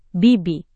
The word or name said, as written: bibi